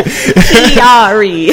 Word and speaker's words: Chiari: Chiari.